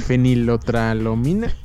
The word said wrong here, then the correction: fenilpropanolamina: Fenilpropanolamina. – Fenilpropalonamina. Eso. Creo que lo dije mal.